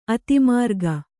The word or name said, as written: ಅಧೋಮಾರ್ಗ